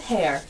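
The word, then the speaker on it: Heyer: But it was Georgette Hare. Am I pronouncing that right? – Yes.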